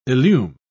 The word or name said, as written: illume